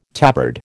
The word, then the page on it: tabard: American English